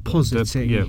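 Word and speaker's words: posseting: Reflux and positing. Is it pronounced positing? Positing? I don't know. – I think of it like deposit. – Deposit. – So, positing.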